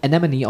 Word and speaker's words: Anemone: Ähm, und im Englischen heißen die Anemone. – Aber wird, wird quasi äh, geschrieben wie im Deutschen oder sehr ähnlich geschrieben, ja. aber wird dann Anemone ausgesprochen.